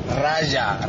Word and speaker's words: Raja: Paco, que no es Raja, que es Raja, Raja de Casablanca, Raja.